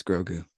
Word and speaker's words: Grogu: Say it right, Will. It's Grogu.